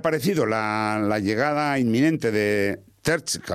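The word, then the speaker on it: Terzić: Bueno, es Edin Terzic,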